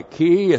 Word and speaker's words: ki: So the conjunction ki, k long I, ki, it's a causal conjunction.